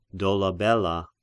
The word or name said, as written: Dolabella